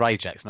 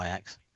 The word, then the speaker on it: Ajax: It's, you know, they're Ajax, not Ajax.